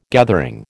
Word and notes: gathering: American English

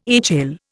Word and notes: HL: Indian

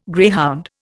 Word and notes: greyhound: Indian

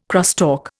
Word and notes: crosstalk: Indian

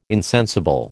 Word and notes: insensible: American English

